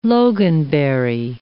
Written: loganberry

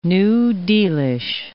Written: New Dealish

